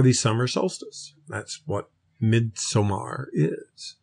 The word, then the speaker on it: Midsommar: We always say Midsommar because that is the British or European pronunciation. The American accepted pronunciation for this is Midsummer. So you'll see some reviewers and people out there who call it Midsummer, even though it has two M's and an A-R, not an E-R in it.